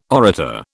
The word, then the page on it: orator: British English